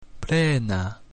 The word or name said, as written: plena